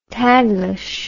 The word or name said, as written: taglish